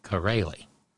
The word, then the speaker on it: Chiarelli: C-H-I-A-R-E-L-L-I. Pronounced Corelli.